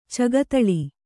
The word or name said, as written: ಚಗತಳಿ